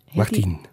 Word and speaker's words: Martien: Urbain Martien. Martin. Martin, zo spreek je het uit.